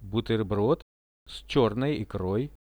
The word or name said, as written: buterbrod s chernoi ikroi